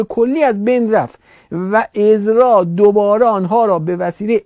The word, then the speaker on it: عزرا: میگه دوباره به اون فرد، به یک نفری به نام عزرا، بعد میگن عزرا، ولی من چک کردم تلفظش عزرا، به عزرا دوباره اینا الهام شد.